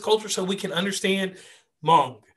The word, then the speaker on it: Hmong: It's with the H, but the H is silent. So it's Hmong, just Hmong.